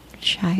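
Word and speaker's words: Chi-Raq: Chirac? Yeah, like Chicago. – Okay, I don't know. – Iraq. Yeah. Yes.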